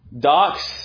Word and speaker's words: doxazo: D-O-X-A-Z-O. A-Z-O. D-O-X-A-Z-O. Now, in the phonetic, there's a D with a hyphen there, so I don't speak Greek, but evidently the Z has a Z sound to it. I don't know. But phonetically, doxazo.